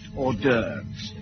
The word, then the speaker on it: hors d'oeuvres: Ooh, or trust horses, doofers. – Actually, Chief, that's pronounced hors d'oeuvres.